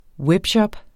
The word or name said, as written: webshop